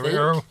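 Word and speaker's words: Loureiro: and I'm going to ask you to say the guitarist's name again because I keep forgetting how to say his last name. – Kiko Larrero, I think. Larrero, yeah, that's right.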